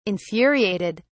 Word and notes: infuriated: British English

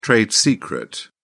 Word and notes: trade secret: British English

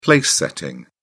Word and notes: place setting: British English

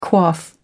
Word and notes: quaff: American English

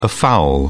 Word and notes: afoul: British English

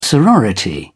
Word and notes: sorority: British English

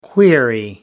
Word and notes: query: American English

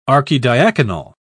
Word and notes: archidiaconal: British English

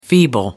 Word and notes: feeble: American English

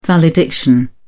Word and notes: valediction: British English

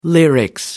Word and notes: lyrics: British English

